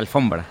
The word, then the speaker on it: alfombra: And when we arrived, there was a beautiful alfombra. Am I saying it right? No. – Yeah, alfombra.